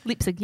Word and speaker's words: Leipzig: Is that how you say it? Leipzig? – Leipzig, yes.